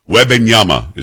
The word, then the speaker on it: Wembanyama: Wembanyama is how you pronounce it.